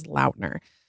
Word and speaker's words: Lautner: Yes, I saw him pronounce his name and it is Lautner.